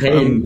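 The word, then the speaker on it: Chełm: Ähm, ihr seid hier gestartet und dann nach, ich glaube, es heißt Kelm, wenn ich es richtig ausgesprochen habe. Kelm ähm, spricht man Kelm, okay.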